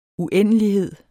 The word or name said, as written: uendelighed